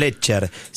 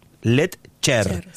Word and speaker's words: Letcher: A los que lo quieran seguir, Hernán Lecher. Se escribe, para que sepan, L-E-T-C-H-E-R. Lecher.